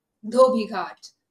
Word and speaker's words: Dhobi Ghat: Dhobi Ghat.